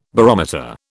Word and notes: barometer: British English

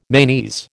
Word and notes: manes: American English

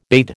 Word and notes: bade: American English

